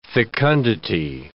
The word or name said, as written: fecundity